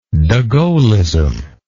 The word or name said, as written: Degaullism